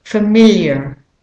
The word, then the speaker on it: familiar: Familiar, familiar. If you're practicing a British or an Australian accent, the AR becomes a schwa. Familiar, familiar.